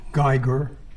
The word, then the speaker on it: Geiger: My name is James Geiger, G-E-I-G-E-R.